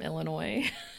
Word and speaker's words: Illinois: Did I know if we were from Illinois? Yeah. Is that where we live? Yes, we are from Illinois. And yes, the S is silent. – That's right. There's only one. It's Illinois, not Illinois.